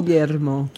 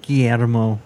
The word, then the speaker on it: Guillermo: Guillermo. – Guillermo.